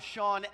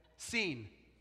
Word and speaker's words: Sean: I'm Sean, S-E-A-N. Okay? Seen. The French way would be Seon.